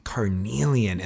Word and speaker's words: Carnelian: And another one is I'm gonna butcher this name, Carnelia. – Carnelian, and yes.